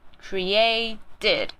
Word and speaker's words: created: That all persons are created 一样，这边也是 t 念成 d 的音。 created， OK。